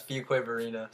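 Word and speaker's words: Fuquay-Varina: I always struggle to pronounce this Fuque Varina. – That's Fuque Varina.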